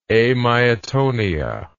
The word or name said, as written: amyatonic